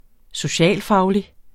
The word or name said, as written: socialfaglig